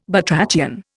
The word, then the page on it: batrachian: Indian